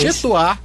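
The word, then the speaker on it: Chez toi: Chetuá, _ em Seu Lugar. Chetuá se escreve Chez, e Toá se escreve T-O-I.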